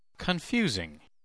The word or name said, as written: confusing